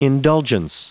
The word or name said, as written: indulgence